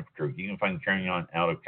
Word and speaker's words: Carignan: Carignan, and it's pronounced Carignan, spelled C-A-R-I-G-N-A-N.